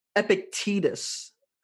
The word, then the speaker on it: Epictetus: But really, really quick, one thing that I saw of what is philosophy, and I came across this quote by um, Epictetus. I said his name right? – Yeah, that's that's right.